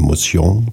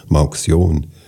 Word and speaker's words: Mauxion: Die Firma nannte sich, französisch ausgesprochen, Mousion. Die Saalfelder haben immer gesagt, Mauxion.